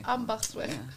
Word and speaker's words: Ambachtsweg: But it's a hard word to pronounce because there's. Twice a G sound in it. And yeah. – Yeah. Ambachtsweg. – Ambachtsweg.